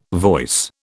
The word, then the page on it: voice: British English